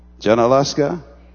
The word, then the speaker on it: Junaluska: And I love Camp Julianuska. Am I pronouncing it correctly? Julianuska? Did I say it right? Huh? Janaluska? My brother, I'm going to hug you afterwards for getting me on course.